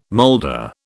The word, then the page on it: molder: British English